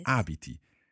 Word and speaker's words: abiti: So the stressed on the first syllable there, yes. – Abiti.